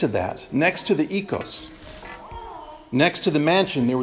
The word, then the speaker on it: oikos: It looks like oikos, like the yogurt that you get in the store. It's pronounced by modern Greeks as oikos.